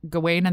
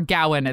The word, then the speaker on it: Gawain: depending on how you want to pronounce it. We're not really sure how people pronounce it. I say Gawain, but I know a lot of people say Gawain.